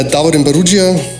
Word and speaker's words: Davorin Barudzija: Ähm, ja, Davorin Barugia, du hast eh schon alles Wichtige gesagt.